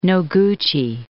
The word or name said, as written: Noguchi